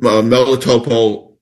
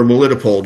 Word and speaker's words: Melitopol: He comes from Melitopol, Ukraine, or Melitopol, depending on how you want to pronounce it, and.